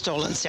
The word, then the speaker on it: Dolenz: And we're speaking to Mr. Mickey Dolenz. – That's close. Yeah. – Mickey, is that how you pronounce it? – Yes, Dolenz. Yeah.